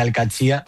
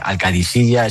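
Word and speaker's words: Al-Qadsiah: Bueno, se suele decir Al-Qadsiya, pero también hay gente que dice Al-Qadisiya. Es que es difícil, ¿eh? No es fácil, pero Al-Qadsiya es lo más fácil.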